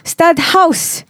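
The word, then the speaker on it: Stadthuys: It's pronounced stud house, by the way. – Stud house. – Walaupun ej dia H-U-I-S, it is house because it's Portuguese.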